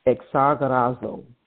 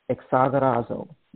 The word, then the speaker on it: exagorazo: So redeem comes from a Greek word, and it is pronounced exagorazo, exagorazo, meaning to rescue from loss.